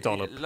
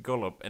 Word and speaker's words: dollop: Stop saying gollop! – Well, even if I'm pronouncing it wrong, you get what I'm saying. – No, because I'm telling you over and over. It starts with a D, okay? You keep saying it with a dollop.